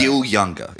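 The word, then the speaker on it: Gil Junger: Gil Younger is how his name's pronounced.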